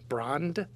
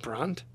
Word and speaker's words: Brand: It was founded specifically as a German-speaking parish, and the first pastor was Father Francis Brand. It's B-R-A-N-D, like brand. Okay, but he would have pronounced it Brandt.